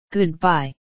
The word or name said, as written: goodbye